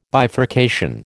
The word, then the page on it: bifurcation: American English